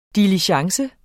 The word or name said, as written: DILIGENCE